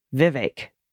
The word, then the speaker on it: Vivek: Ramaswamy was good, but Vivek rhymes more with bake than heck. Vivek. All right. And there's an equal emphasis on both syllables.